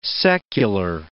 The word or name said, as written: secular